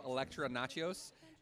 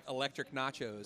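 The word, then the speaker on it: Elektra Natchios: It's the first time I've heard her say her name, Elektra Nachos, and not have it sound like Electric Nachos.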